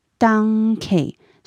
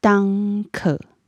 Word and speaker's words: Danke: 他们两个差别在哪里？他们差别在尾音。像我刚刚说的 “danke” 是奥地利的发音，那像德国的发音呢，我们发 “danke”。所以在尾音上面会有一点不一样。